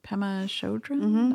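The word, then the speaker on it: Pema Chödrön: And she talks about, what's Pema Chodron? That's how you pronounce it.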